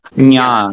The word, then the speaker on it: Naya: it does not work in Naya as much as I hope. But... That's because you're pronouncing Naya wrong. It's Nya.